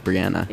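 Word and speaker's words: Breanna: Fun fact, her name's pronounced Brianna.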